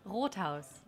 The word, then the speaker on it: Rothaus: Rothaus? – Oh, we have a German pronunciation. – Okay, say it one more time. Say it in the mic. – Rothaus. – There you go.